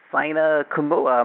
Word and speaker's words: Saina Kamula: Today I have Saina Kamula.